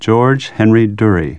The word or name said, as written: Durrie